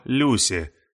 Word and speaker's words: Люси: Она назвала его Люси, с ударением на первый слог.